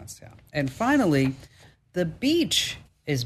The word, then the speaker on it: Bitche: It's the town of Beach, France, but it's spelled B-I-T-C-H-E.